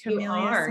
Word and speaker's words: Kamilia: Kamilia Smith? – Yes.